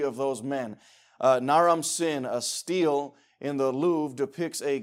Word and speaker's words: stele: I know that word looks like stele, but you pronounce it steel.